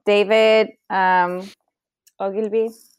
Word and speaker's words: David Ogilvy: So this is David Ogilvy.